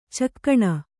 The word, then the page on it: ಚುಕ್ಕಣ: kannada